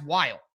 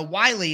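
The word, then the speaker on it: Whyle: But it's W-H-Y-L-E. To me, that's Wile. So Wiley is quite confusing that that would be the pronunciation.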